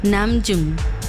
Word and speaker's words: Namjoon: Namjoon.